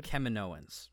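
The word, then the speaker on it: Kaminoans: Keminoans. That's how they say it.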